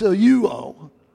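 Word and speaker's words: seluo (σαλεύω): Seluo, it's spelled S-E-L-E-U-O, but it's pronounced seluo. Seluo.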